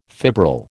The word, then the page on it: fibril: American English